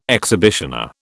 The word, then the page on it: exhibitioner: British English